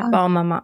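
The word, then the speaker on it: Bonne Maman: No, I think you got it. I think it's Bon Maman, whatever.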